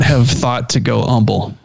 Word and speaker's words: Humble: I believe it's Humble, like without the H.